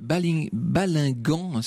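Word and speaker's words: Balinghem: Et au niveau de cette départementale qui lie donc Guînes à Ardres, au niveau de Balingan, ça s'écrit Balingem, mais ça se prononce Balingan, vous avez la fameuse stèle commémorative dont on parlait tout à l'heure.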